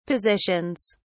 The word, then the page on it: positions: British English